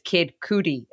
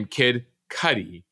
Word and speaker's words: Kid Cudi: I appreciate it because at least now I'll never mispronounce it as Kid Cootie again. – Uh, you could go two directions, but just to let you know, Scott Mescudi became Kid Cudi for all of you looking at your early 2000s hip hop history.